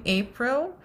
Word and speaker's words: Apryll: So my name, April, is spelled differently.